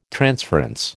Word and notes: transference: American English

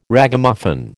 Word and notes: Ragamuffin: American English

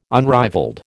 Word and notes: unrivalled: American English

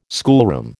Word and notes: schoolroom: American English

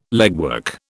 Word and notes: legwork: British English

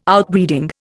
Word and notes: Outbreeding: Indian

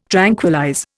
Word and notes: tranquillize: Indian

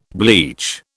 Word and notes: bleach: British English

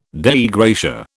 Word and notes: Dei gratia: British English

